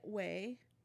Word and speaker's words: Ouai: It's by Way, which is spelled O-U-A-I.